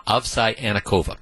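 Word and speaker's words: Ovsyannikova: Her name is Marina Avsyankova. Avsyankova is how you pronounce it.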